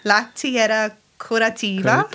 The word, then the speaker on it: La Tierra Curativa: And we've all just had a big giggle about the computer working and the computer not working and pronunciation of La Tierra Curativa. How did I go? – Yep. – Okay. – Yep. Yes, definitely your energy affects electronic devices.